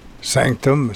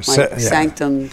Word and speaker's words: SNCTM: which I guess is pronounced Sanctum. – Sanctum? – Sanctum.